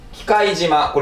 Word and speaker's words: 忌怪島: 機械島これ機械島と読むんですね